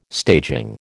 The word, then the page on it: staging: American English